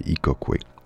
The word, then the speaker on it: Ikoqwé: Ikokwe, il faut le prononcer Ikokwe.